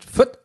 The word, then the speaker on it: Fürth: Man spricht Fürth. – Fürth. – So kurz aus. – Fürth. Oder ich. – Nein, das war schon richtig. – Ach so. – Fürth. Spricht man so kurz aus, dass man es eigentlich, dass nur Insider noch wissen, dass es sich um den Namen der Stadt handelt.